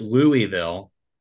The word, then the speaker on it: Louisville: It's not Louisville. It's Louisville. I don't care what anybody has to say about it. It's Louisville.